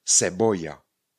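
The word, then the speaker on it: cebolla: V momentě, když tam bude E a I, třeba CE eh, nebo CI, tak budeme vyslovat právě trošičku jinak to C. A ne jako K, ale jako, tušíte, jako S. Třeba slovo seboja. Seboja. Seboja znamená cibule. A píše se C, C, E, B, O, L, L, A seboja, cibule, seboja.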